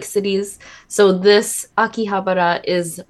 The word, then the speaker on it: Akihabara: Okay, Akihabara. – Akihabara.